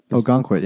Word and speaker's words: Ogunquit: Ogunquit, yeah.